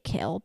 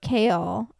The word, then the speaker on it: Chaol: But him, so Dorian, the prince, and Kael, some people just say Kael because it's low-key a lot easier, but it's spelled C-H-A-O-L. And so Kael, I say Kael, they're besties.